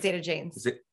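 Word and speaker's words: Zeta-Jones: If you're local to Charlotte, I mean, it was Jada Zanes, right? – Zeta Janes. – Am I saying it right? – Yes. – What did I just do? – You said Jada Zanes.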